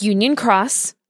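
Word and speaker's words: Union Cross (Union χ): Kingdom Hearts Union Cross. It has an X, but it's pronounced cross because it's Japanese.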